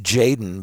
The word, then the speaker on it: Jxdn: It's pronounced Jaden, but it's spelled J-X-D-N.